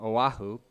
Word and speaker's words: Oahu: And as I said that wrong, Oahu, my bad.